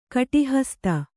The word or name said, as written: ಕಟಿಹಸ್ತೆ